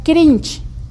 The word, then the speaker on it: cringe: se le ha puesto la palabra cringe. Cringe es como se pronuncia, pero se escribe cringe. CRINGE.